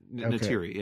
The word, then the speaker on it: Neytiri: Neytiri, yeah.